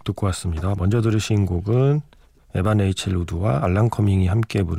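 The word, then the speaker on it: Evan: 노래를 부른 건 우리가 보통 에반 레이첼 우드라고 하는데 원래 발음은 아마 이반 레이첼 우드가 가까울 겁니다.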